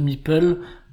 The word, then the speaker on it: Meeple: Une espèce de figurine, c'est le Meeple. Le Meeple, qu'on prononce M-I-P-E-U-L, hein, comme c'est précisé, je crois, dans Carcassonne, mais qui s'écrit M-E-P-L-E.